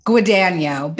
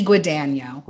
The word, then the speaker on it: Guadagno: How do you say your last name? – Guadagno, Betty Guadagno. – Guadagno.